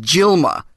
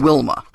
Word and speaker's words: Dilma: It's pronounced Dilma, spelled Dilma, and not Wilma.